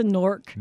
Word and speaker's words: Knork: It's a Nork. – Nork. – How do you spell that? – K-N-O-R-K.